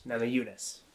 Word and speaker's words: Namajunas: Um, Rose Namajunas. Is that how you pronounce it? Yeah. Yes. Namajunas.